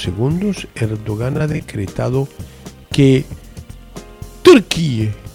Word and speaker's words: Turquía: Y se escribe TURKIYE. Me investigué y se dice Turquía. – Se dice Turquía, sí, así salen los álbumes de fútbol, por ejemplo.